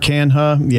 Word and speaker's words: Canha: Riley Green, Kerry Carpenter, They got the, what's the guy, Canha? How do you say that? – Mark Canha. Yeah.